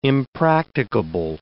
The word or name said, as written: impracticable